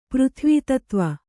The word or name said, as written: ಪೃಥ್ವಿತತ್ವ